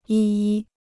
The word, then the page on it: 一一: yī yī